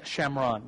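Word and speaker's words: Shomron: And it says this, John chapter 4, verse 5 says, he came to a town in Shamron, which is the Jewish pronunciation of Samaria, called Shechem, near a field of Yaakov, Jewish pronunciation of Jacob.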